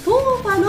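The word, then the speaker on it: 豆花: さあ、こちらのブースではこの後、台湾のヘルシースイーツトーファの試食タイムがスタートいたします。豆の花と書いてトーファと読むこちらのスイーツ、数年前から日本でもブームが起きており、豆乳の優しい風味とほろっとした食感が癖になる今大人気のスイーツです。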